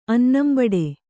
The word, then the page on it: ಅನ್ನಂಬಡೆ: kannada